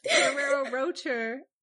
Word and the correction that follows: Ferrero Rocher: Are you trying to say Ferrero Rocher? – Yes.